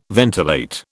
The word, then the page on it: ventilate: British English